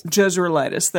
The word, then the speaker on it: Jezreelitess: How do you say this? Jezreelitess. – Yeah. – She's from Jezreel. So, yeah. – Jezreelitess. There it is.